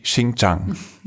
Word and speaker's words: Xinjiang: Und ihr seid ja in China durchaus auch tatsächlich dann durch sehr problematische Regionen gefahren, zum Beispiel durch Xinjiang, genau.